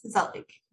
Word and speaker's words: gezellig: In case you do want to Google it, Zelig, which I'm saying the best I can, is spelled GEZELLIG. So if you're looking it up later, it, at least for me, I'm like, I can't look at it and say it right, but yeah, it doesn't look how it sounds. – The G is an H.